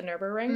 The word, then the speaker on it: Nürburgring: The Nürburgring.